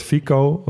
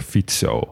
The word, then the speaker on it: Fico: Ja, in de Nederlandse media zal die waarschijnlijk ook Robert Fico worden genoemd. Want zo zouden wij het gewoon uitspreken. Maar een C in het Slowaak spreek je uit als TS. Dus vandaar Robert Fico.